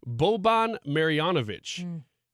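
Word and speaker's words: Marjanović: Boban Marjanovic is a man of the people.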